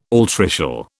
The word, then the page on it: altricial: British English